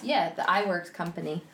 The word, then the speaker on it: Iwerks: So Oob Eworks, I don't know how to say this, Iwerks, okay.